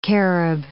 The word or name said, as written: Carib